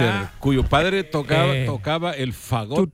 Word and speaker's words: fagot: El fagot es como una trompeta, sí. – En inglés es bien feo lo que significa. – Como clarinete, fagot, pues. – Pero es con acento en la A.